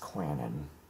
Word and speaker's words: Quannon: It's an southern name. It's pronounced Quannon.